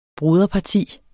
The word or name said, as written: broderparti